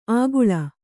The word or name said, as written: ಆಗುಳ